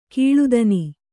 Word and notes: ಕೀಳುದನಿ: Kannada

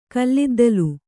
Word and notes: ಕಲ್ಲಿದ್ದಿಲು: kalliddilu

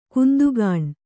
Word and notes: ಕಾಂತಗುಣ: kāntaguṇa

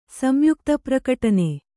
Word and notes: ಸಂಯುಕ್ತಪ್ರಕಟನೆ: samyukta prakaṭane

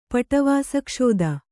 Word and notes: ಪಟವಾಸಕ್ಷೋದ: kannada

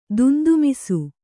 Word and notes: ದೊಂದಿಮೀಸೆ: dondi mīse

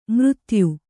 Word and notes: ಮೃತ್ಯು: kannada

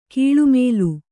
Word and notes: ಕಳ್ಳಮಾಲು: kaḷḷamālu

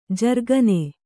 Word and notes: ಜರಕ್ಕನೆ: jarakkane

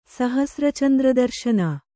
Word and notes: ಸಹಸ್ರಚಂದ್ರದರ್ಶನ: sahasra candra darśana

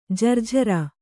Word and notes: ಜರ್ಝರ: Kannada

